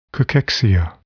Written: cachexia

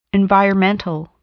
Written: environmental

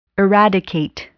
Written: eradicate

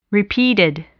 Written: repeated

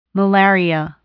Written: malaria

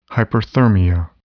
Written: hyperthermia

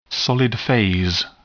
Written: solid phase

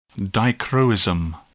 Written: dichroism

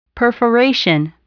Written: perforation

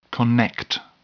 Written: connect

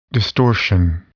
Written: distortion

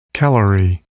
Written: calorie

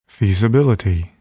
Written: feasibility

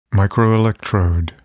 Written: microelectrode

